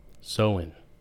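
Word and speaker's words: Samhain: And that's where the same hain, or what'd you call it earlier? – Sowin. – That's the print. – Like the Celtic pronunciation, yeah.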